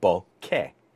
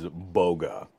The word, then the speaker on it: bokeh: But the sort of general pronunciation would be bokeh. So it's the thing I'm trying to get people away from is boga, where it's almost like B-O-G-U-A or something like that.